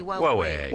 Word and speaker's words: Huawei: ¿cómo se dice? Huawei, no sé, bueno. – Sí, Huawei. – Huawei, vamos a decirle Huawei.